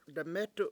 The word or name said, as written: demEtu7